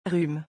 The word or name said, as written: rhume